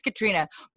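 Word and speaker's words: Caitriona: I know her name is Katrina.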